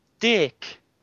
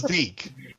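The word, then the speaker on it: DiC: You see, it keeps being pronounced Deke, but what was weird was every time the show ended and the logo came up, we used to laugh our asses off here, because there'd just be the sort of womanly voice that would say, Dick!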